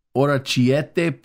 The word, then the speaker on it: orecchiette: Let me see if I can pronounce this correctly. Orecchiette pasta with Parmigiano Reggiano. Is that pretty good? – Yes.